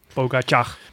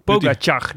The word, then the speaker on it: Pogačar: Pogačar.